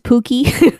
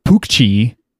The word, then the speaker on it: Pucci: I think if anything, it would be like Pucci. Because C Pookie. – And then a vowel in Italian is pronounced ch. – Mm-hmm. – It's backwards from English. CH is pronounced k. That's why it's zucchini, not zucchini.